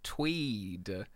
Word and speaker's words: TUIDE: And they have a brand new girl group that have debuted this week called Tweed, is I believe how it's meant to be pronounced. It's spelled T-U-I-D-E. The kind of explanation that they've given is, "We tune the tide," which would make me think that it's pronounced like Chwide or Chew-eyed or Two-eyed, but apparently it's Tweed, because K-pop groups can't be named normally.